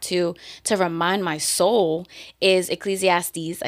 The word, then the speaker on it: Ecclesiastes: Because she was saying Ecclesiastics. Ecclesiastics. Ecclesiastics.